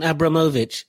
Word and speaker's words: Abramovich: What do you think about the about, actually, I'm going to pronounce it properly, Roman Abramovich.